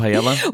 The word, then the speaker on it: paella: We're going to paella. – We are if you're from England.